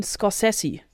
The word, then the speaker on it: Scorsese: Ich habe nochmal extra in der ARD-Aussprachedatenbank nachgehört, Martin Scorsese.